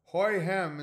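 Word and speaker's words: Houyhnhnm: Hoy hem is pronounced like horses neighing.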